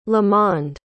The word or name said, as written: lamond